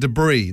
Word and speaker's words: debris: Number 5, you don't pronounce the S in debris. This is a bit like salmon. Who says debris? – Wait, there's an S in debris? – Well, it's spelled D-E-B-R-I-S.